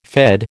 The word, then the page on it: Fed: American English